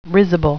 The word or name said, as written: risible